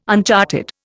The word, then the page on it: uncharted: Indian